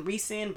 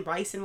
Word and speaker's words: ricin: It's R-I-C-I-N, ricin, ricin, whatever it is, ricin.